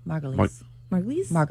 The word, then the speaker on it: Margulies: I did look up how to say her name. Juliana Margulies? – Margulies.